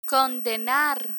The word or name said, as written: condenar